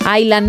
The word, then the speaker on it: Island: En Island no pronuncian la S.